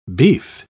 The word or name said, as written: beef